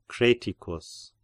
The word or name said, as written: Creticus